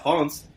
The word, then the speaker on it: Reims: Yeah, but yeah, Reims.